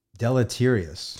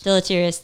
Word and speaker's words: deleterious: Many factors account for the, how do you say this word? – Deleterious. – Deleterious, thank you.